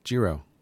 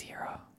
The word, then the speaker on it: Jiro: Jiro. – Jiro? – In my head, it's Jiro with a J. – I think it's Hero. – You think it's Hero? – Soft J.